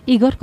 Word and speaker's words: Igor: Igor, con acento en la I, con acento ruso, algo que no gustó demasiado al cura que lo bautizó, porque ese nombre le sonaba a rojo y comunista.